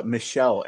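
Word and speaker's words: Michel: Okay, let's Michael Ansel. – Michelle Ansel. Michelle. See, you said this, and I'm stupid. Michelle Ansel. – You're not stupid. You're just pronouncing it the way it looks like it should be pronounced.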